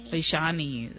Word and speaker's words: Aishani: I know it's spelled A-I-S-H-A-N-I, but do you pronounce it Ishani or Oishani? – Oishani.